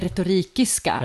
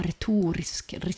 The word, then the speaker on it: Retorikiska: Ja, det är därför det heter retorikiska. Ja. Alla har jättesvårt att uttala det. Retorikiska, det är som ett språk. Turkiska, grekiska. De har retorisk, och så fastnar de.